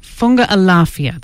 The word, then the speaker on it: Funga Alafia: They're starting off with a song called Funga Alafia. Did I say that right? Anne, is that correct? Funga Alafia, there you go.